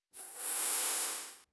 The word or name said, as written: 赞叹